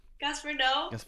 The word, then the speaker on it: Gaspar Noé: And so I think that Gaspar Noé, how do you say his name again? Gaspar Noé.